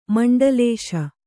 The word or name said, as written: ಮಂಡಲಿಸು